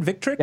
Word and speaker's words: Victrix: I'll let you get a plug in here for Victrix. Am I pronouncing that right? Victrix? – Yeah, Victrix. Victrix, yes. That Fight Stick, the Pro FS, is coming out pretty soon.